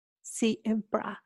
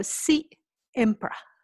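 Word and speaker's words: Si'Empra: Siempre. So it's S-I, S for Sydney, I, and then it has an apostrophe. – Okay. – E-M-P-R-A. So Siempre.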